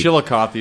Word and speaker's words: Chillicothe: Chillicothe is the pronunciation.